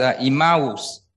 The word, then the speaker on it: Emmaus: So I'm going to say it as Emmaus. It's not that I do not know how to pronounce it, but that's the Greek way to pronounce it.